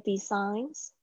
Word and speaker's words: designs: Do you have any other designs? Do you have any other？就是你有其他的什么东西？Designs，注意一下这个 G 在这边呢是不发音的 designs。